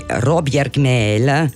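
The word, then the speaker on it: Råbjerg Mile: Pensate che la storia di Robjergmel, che si scrive Robjergmile, ma si pronuncia così, comincia oltre 300 anni fa nella zona costiera chiamata Robjergsten, sul lato ovest dello Jutland, affacciato allo Skagerrak.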